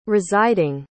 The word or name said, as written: residing